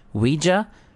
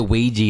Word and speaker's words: Ouija: So, apparently, the official pronunciation, Ouija or Ouija. – So, yeah, I went the Ouija, and I thought, it sounds too much like squeegee.